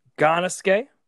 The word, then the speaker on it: Gannosuke: So I started with a match from War in '95 against Tarzan Goto and Gannosuke. I'm saying that right? – Donosuke. – Gannosuke. – Yeah. – Tarzan Goto and Gannosuke Yep.